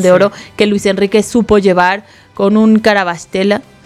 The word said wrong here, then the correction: Kvaratskhelia: se dice así, ¿no? – Kvaratskhelia también, sí, espectacular, ¿no?